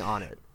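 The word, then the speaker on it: Onett: We pronounce it Onet, right? – You're going to be mad. – No way! – I say Onet! – Oh, okay. At least you don't. – No, I don't. I don't do the cursed pronunciation. – Okay. – Good. I do not do the cursed pronunciation. I say on it.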